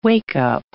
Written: wake-up

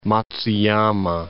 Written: Matsuyama

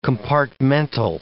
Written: compartmental

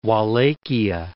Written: Wallachia

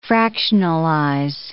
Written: fractionalize

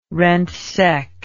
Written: rentseck

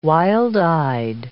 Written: wild-eyed

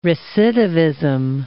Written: recidivism

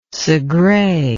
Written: Segre

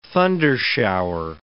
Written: thundershower

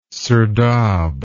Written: serdab

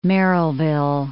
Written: Merrillville

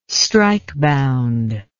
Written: strikebound

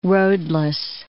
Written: roadless

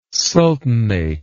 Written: sultanate

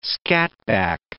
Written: scatback

